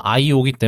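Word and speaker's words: iyO: 근데 그 와중에 여기 중에 하나가 이제 IO인데 여기 IYO예요. 근데 발음을 하면 IO이기 때문에 네.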